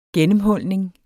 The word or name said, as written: GENNEMHULNING